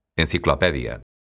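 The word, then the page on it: enciclopedia: Galician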